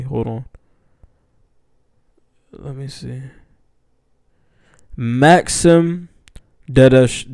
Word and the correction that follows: Dadashev: Maxim Dadashev.